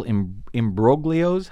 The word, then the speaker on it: imbroglio: Writes, the past 24 hours of news and President Donald Trump's various legal imbroglios. How do you say that? – Imbroglio.